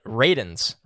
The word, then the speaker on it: Radunz: I saw somebody said it was like raisins with a D, so Radunz.